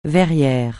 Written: verrier

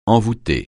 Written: envoûter

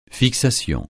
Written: fixation

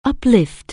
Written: uplift

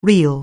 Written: real

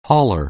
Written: hauler

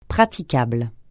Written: praticable

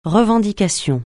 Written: revendication

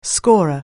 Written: scorer

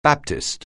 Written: Baptist

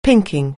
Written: pinking

